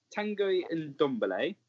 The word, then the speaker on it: Ndombele: So, Tanguy Ndombele, that's how you pronounce it.